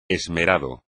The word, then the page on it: esmerado: espagnol